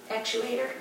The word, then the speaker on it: actuator: So on page 4, and while they were here looking at the chiller, they noticed that cold water actuaries, am I saying that right? – Actuator. – I always say actuary.